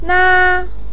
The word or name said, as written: na1